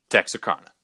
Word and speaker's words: Texarkana: Four brutal crimes occurred in less than 3 months in Texarkana.